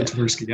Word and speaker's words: Tversky: Is that how you pronounce his last name? – That's right. Yeah, Kahneman, yeah. Tversky.